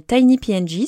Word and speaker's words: TinyPNG: Et pour ça, bah, vous pouvez utiliser un excellent outil qui s'appelle TinyPNG. Ça s'écrit T-I-N-Y-P-N-G.